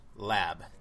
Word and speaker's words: Lab: It's pronounced Lab. That's it. – Yeah, yeah. I hear people say Lob. I like that. That's funny. Because like Lob is like a dish, right? – I think it is, yeah.